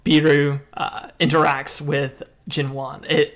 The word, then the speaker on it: Beru: B-E-R-U. How would you pronounce that? – Beru. – Beru?